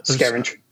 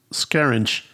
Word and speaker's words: Scaringe: Uh, how do you pronounce last? Is Scaringe? – Scaringe. – Scaringe.